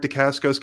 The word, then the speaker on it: Dacascos: And I think the fact that Mark Dacascos can switch,